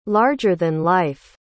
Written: larger-than-life

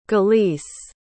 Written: galice

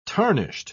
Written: tarnished

